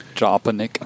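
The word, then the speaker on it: Jalopnik: How do you pronounce it? Jalopalink or Jalopanik? – Jalopanik.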